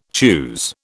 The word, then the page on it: choose: British English